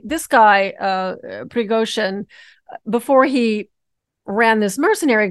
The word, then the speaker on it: Prigozhin: Well, I thought it was interesting because I hope I pronounced this man's name correctly. Yevgeny Prigozhin. – Yevgeny Prigozhin. – Prigozhin, thank you.